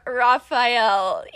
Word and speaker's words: Raphael: So here is Raphael.